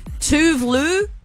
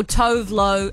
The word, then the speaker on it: Tove Lo: It's two tracks, one artist, two ways to say her name. She even says it. Tove Lo or Tove Lo, however you like it.